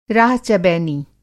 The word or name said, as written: राह-चबैनी